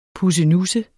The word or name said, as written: pussenusse